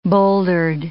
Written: bouldered

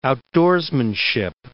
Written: outdoorsmanship

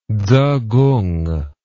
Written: tzekung

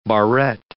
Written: barrette

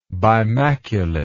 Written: bimaculate

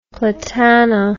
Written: platanna

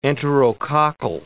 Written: enterococcal

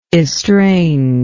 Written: estranger

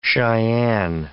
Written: Cheyenne

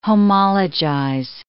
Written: homologize

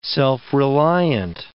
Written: self-reliant

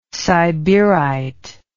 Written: siberite

